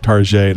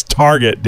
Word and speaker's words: Target: Of course, you get fancy pronunciations these days that, like, you know, my wife always calls Target Tarjay, and I'm like, what the hell? It's Target, damn it.